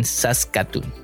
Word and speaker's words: Saskatoon: ¿Por qué no a grandes rasgos nos comparten cómo es un día común y corriente en Saskatoon.